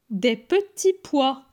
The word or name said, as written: Des petits pois